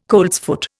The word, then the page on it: coltsfoot: Indian